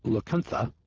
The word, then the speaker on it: Lacunza: Well, here's the story about Emmanuel Lacuntza, spelled L-A-C-U-N-Z-A, but pronounced T-H-A, and the book that he wrote.